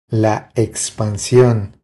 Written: La expansion

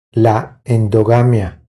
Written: La endogamia

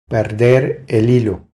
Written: Perder el hilo

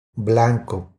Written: Blanco

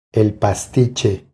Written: El pastiche

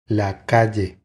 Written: La calle

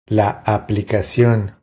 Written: La aplicacion

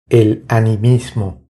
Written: El animismo

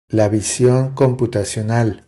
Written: La vision computacional

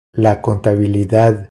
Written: La contabilidad

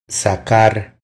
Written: Sacar

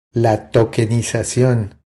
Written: La tokenizacion

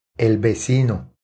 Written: El vecino

